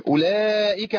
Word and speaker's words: أولئك: خلاص الواو هنا غير منطوقه خلاص لا تنطق. يبقى ايه؟ اولى من قال اولى يبقى زاد في القران واو ليست في قراءة النبي صلى الله عليه وسلم. شوف خطورة المسألة. أولئك على هدى من ربهم أضغمت الإيه؟